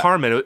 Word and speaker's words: Carman: And it was Carmen. It's spelled like Carman. – Yeah. What happened? – C-A-R-M-A-N.